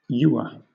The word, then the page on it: ewer: British English